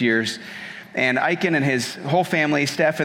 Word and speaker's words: Isken: It's spelled I-S-K-E-N, but it's pronounced Iken.